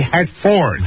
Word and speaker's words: Hartford: $1 for you, Ross, and the correct pronunciation would be Hartford.